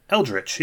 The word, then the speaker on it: Eldritch: Is it Eldritch or Eldrick? I don't know. – Eldritch? How do they spell it? – I think it's TCH. – Eldritch, yeah. – Yeah. – Like, Witch. – Yeah, Eldritch Evolution. – Stitch or Rich.